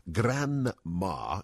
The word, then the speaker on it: Granma: Granma, yes, you heard right, it's spelt G-R-A-N-M-A, Granma,